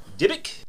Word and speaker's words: dybbuk: Yeah, we need to know what you know about Dybbuk. – Dybbuk? – Dybbuk. – D-Y-B-B-U-K? – Yes.